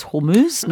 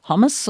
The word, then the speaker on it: Hormuz: It's Hormuz, not hummus or something.